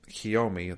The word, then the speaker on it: Kiyomi: How do you pronounce her name? Kiyomi. They say Kiyomi-san.